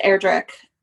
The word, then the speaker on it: Erdrich: And I think her last name is pronounced Erdrich, not rhyming with witch.